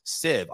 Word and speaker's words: sieve: Siv, I apologize. It's spelled S-I-E-V-E, by the way. So that's why I mispronounced this.